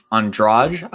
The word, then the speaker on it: Andrade: So up next we have Andrade, I believe it's pronounced. – Yeah.